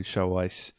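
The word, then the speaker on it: XiaoIce: Yeah, if it's being tested down in the Chinese market, that's why Show Ice is pronounced like that with the X and the S, isn't it, for Chinese? So the only thing I thought was a bit odd is it's not an official video, is it? – No.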